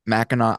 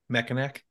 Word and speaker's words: Mackinac: And then one whose name I just learned to pronounce correctly last summer, Mackinac Island Fudge. – How'd you say it before? – Mackinac. – Mackinac. It looks like Mackinac. It's NAC. Fair. It's NAC. Mackinac. How do you get Mackinac from that? – Silent, dude.